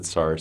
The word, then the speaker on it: Tsar: Has the Tsar seen the telegram? – 对，points for pronouncing Tsar correctly.